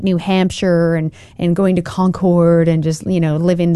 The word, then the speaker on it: Concord: Concord is affecting the politics of, I said that right, yeah, affecting the politics that happens everywhere else, right, mm-hmm.